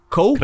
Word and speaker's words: Koch: Coach Media, uh, K-O-C-H. – Coke. E Coke?